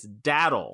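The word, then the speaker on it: datil: Jason was actually the closest, really close, but the correct pronunciation is daddle.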